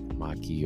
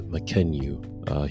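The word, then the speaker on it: Mackenyu: One of the names of the actors I mispronounced, and it's, I think I say like Maki or whatever, but it's Makenyu. He's a Japanese actor, he plays Zoro, so if you hear that in the review, so think of Makenyu, not whatever I said before.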